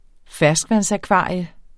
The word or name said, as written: ferskvandsakvarium